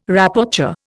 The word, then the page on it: Rapporteur: Indian